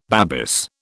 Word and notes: Babis: British English

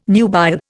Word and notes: nubile: Indian

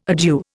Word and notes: adieu: Indian

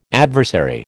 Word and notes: adversary: American English

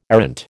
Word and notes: errant: American English

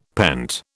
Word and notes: pent: British English

